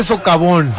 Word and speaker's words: socavón: Y es culpa también del sobacón que se abrió ahí a un lado de las vías del tren. – Canícula, y no se dice sobacón, se dice socavón.